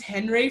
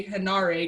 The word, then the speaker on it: Henare: So I thought it was Henry for the longest time, but then I saw they spelled it like the other way. So I was like, oh my God, that's not what it is. And I was like, I hope I hadn't been saying his name wrong this whole time. So I've been slowly teaching myself to say Henare because that's how it's spelled.